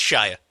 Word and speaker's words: Shia: I don't even know if I'm saying that right. LaBeouf. – It's Shia. – Shia. Shia LaBeouf.